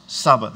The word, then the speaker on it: tsaba: It's T-S-A-B-A, pronounced Saba. The T is silent, like Saba, but it starts T-S-A-B-A. It refers to, as far as the heavens are concerned, every resource, including angelic hosts.